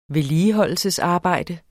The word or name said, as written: vedligeholdelsesarbejde